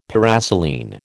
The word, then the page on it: paraselene: American English